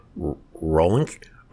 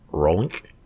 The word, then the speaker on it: Rolenc: I can't even pronounce his last name. Rolenk, R-O-L-E-N-C, Rolenk.